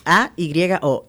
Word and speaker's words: Ayo: No, no, no, Ayo se escribe e eh, A Y O, Ayo.